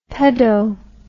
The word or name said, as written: pedo